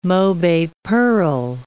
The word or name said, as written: mobe pearl